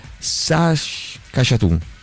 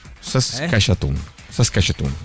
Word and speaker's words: Saskatchewan: Si legge Saskatchewan. Saskatchewan.